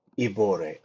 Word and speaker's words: Ibori: Their signatory is a man named, forgive my pronunciation, Bubaka Ibori. – Dubakar Dibore, would you say? – Ibore, I-B-O-R-I.